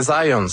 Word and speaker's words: Zajonc: Non, c'est Zajonc. – Ouais? OK. – J'ai-tu pas dit Zajonc? – Vous avez dit plus Zajonc, mais... – Je l'ai prononcé un petit peu à l'anglais. J'ai prononcé mon polonais à l'anglais.